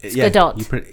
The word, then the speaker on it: Gadot: Is it Gadot? – Yes, it's Gadot. – She's Israeli, so you pronounce the T for some reason.